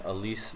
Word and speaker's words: Alize: Aaron Holiday from UCLA, and Alize Johnson.